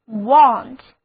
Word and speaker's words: want: Desire 有两个近义词，一个是 want, W-A-N-T, want. A 字母发 O 的音。单词就读作 want，意思是要求、需要。